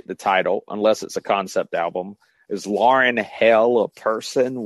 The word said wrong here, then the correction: Laurel: Well, it's pronounced Laurel. – Oh, that's so. Right. It's Laurel, not Lauren.